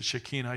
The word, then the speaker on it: Shekinah: And the holiest place is filled with smoke, which is also linked to the Shekinah glory, or Shekinah, depending on your, I guess the Hebrews pronounce it Shekinah. I checked with some. Is that the right pronunciation? Most of us would say Shekinah glory, but they say Shekinah glory.